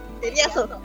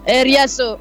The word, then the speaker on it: eriazo: Hola a todos, hola Radio Placeres, todo bien aquí en el sitio de la Eraso. ¿Cómo se dice? – Eriazo. – Eriazo, ok.